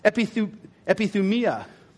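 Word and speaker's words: epithumia (ἐπιθυμία): And it's pronounced epithumia. Epithumia.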